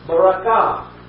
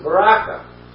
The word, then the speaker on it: barakah: Blessed is a Hebrew word, some of you will recognize, which is barakah. Some pronounce it barakah. That's spelled B-A-R-A-K-A-H.